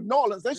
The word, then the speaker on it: New Orleans: 14 years old from Louisiana, a little town just outside of New Orleans. – Orleans, and you said that right too. New Orleans.